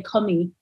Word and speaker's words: commis: You mentioned the figure, I might not be pronouncing it right, of the commie, of the commis. – Well, it's pronounced like the French way, commis.